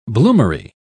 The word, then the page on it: bloomery: British English